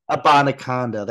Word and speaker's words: Abanikanda: Um, uh, keep, I always pronounce this guy's name wrong. I, I mentioned him last name. Uh, my sleeper is the running back from Pittsburgh. Um, Israel Abanikanda. Abanikanda, that's what it is.